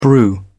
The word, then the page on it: brew: British English